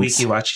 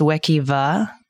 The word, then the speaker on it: Wekiwa: So it was actually, I think it was like something like, it's actually pronounced Wekiwa, but people fucked up and made it a W. Mm-hmm. Something about like there's always a silent W.